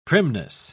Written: primness

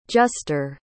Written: juster

